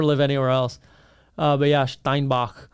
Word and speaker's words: Steinbach: Well, Steinbeck is Stony Steinbach. – Brook. – Steinbach. – Steinbach. – Yeah, you gotta pronounce it correctly.